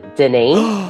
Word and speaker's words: Denis: So it is Denis, like the Denis Mountain in France.